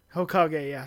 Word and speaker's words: Hokage: He was nominated 8th is that pronounced Hokage? – Hokage, yes.